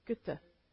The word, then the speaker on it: Goethe: I wanted to leave you with this quote that some people think is by, is it Goethe? Goethe, is that how you say it? Goethe. I had to look it up.